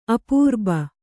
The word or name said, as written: ಅಪೂರ್ಬ